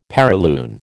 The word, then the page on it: perilune: American English